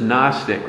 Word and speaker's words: Gnostic: In English, the G is silent, so it's just Gnostic rather than Gnostics.